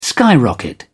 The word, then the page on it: skyrocket: British English